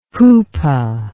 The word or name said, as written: Hupa